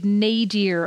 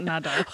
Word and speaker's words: nadir: Well, and then the absolute nadir of all of this was, is that the correct pronunciation? Nadir. Nadar? – Definitely not Nadar.